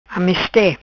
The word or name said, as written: Ameste